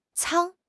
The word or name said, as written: 舱